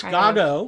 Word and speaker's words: Godot: It's Godot. Of, yeah. – And there, the pronunciation, very important there, Waiting for Godot.